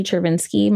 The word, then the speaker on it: Chervinsky: So my website is Lindsay Chervinsky.